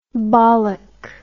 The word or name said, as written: bollock